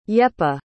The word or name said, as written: yepa